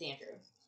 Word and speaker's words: Andreu: It's spelled A-N-D-R-E-U. – Okay. – But it's Andrew.